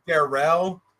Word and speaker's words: Darrell: Darrell, it's spelled like Daryl, but it's pronounced Darrell.